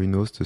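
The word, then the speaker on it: YunoHost: Alors, YouNost, c'est le Y qui se prononce Y en anglais. Donc, c'est YouNost.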